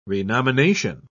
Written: renomination

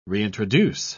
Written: reintroduce

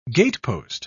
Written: gatepost

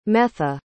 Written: metha

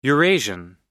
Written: eurasian

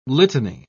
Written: litany

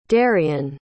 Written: derion

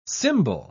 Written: symbol

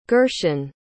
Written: gershon

